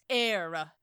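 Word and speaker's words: Éire: And Seamus is like, "Why don't you learn how to pronounce it, boyo? It's air."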